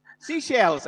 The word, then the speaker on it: Seychelles: How do you pronounce this? Seychelles? Seychelles, ayan, Seychelles.